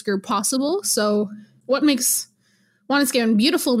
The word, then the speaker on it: Wanuskewin: So my master's thesis is focusing on plains archaeology, of course, specifically around the Apimahau Valley, which is the valley where Wanuskewin or Wanuskewin, if you say it properly in Cree, is situated.